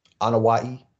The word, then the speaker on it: Anoa'i: So, but the bloodline that they're talking about is the Anoa family. – The Anoa'i family. – Oh, is that how you pronounce it? – Yeah. – Okay. – Pronounce that one more time. – Anoa'i.